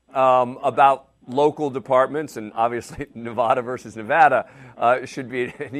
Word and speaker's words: Nevada: But you got to tell your co-hosts, you know, Tom Morris Jr. and Sean Stick Larkin, how to pronounce Nevada. It's not Nevada, it's Nevada.